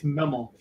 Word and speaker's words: Memel: It's Memel.